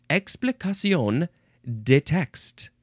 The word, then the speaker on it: explication de texte: Oh, it's French. Yeah, I should have looked at the etymology. Explication de texte.